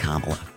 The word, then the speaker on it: Kamala: Except, like, it's pronounced Kamala.